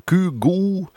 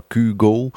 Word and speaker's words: Kygo: We gingen even terug naar 2018 met, ik heb het even opgezocht, de Noorse uitspraak is Kygo of Kygo. En de Anglo-Saxische wereld, daar wordt de naam uitgesproken als Kygo.